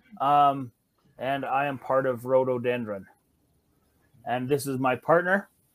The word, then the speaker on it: Rhododendron: Rhododendron.